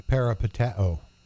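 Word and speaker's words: Peripateo (περιπατέω): Peripateo.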